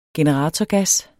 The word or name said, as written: generatorgas